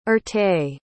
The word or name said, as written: erté